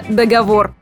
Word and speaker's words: договор: Прочь всякие сомнения и ударения на последний слог. И будет исключительно договор.